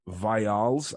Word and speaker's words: Vialls: Viales, I think is how you say his name, V-I-A-L-L-S.